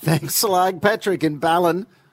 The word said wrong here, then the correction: Ballan: Ballan. – Ballan, mate. Come on. Ballan. It's not that hard to pronounce.